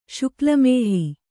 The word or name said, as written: ಶುಕ್ಲಮೇಹ